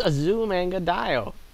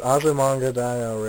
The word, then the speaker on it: Azumanga Daioh: Yes, however you want to pronounce it, you go right ahead. – It's Azumanga Daioh. – It's Azumanga Daioh. They pronounce it right in the show.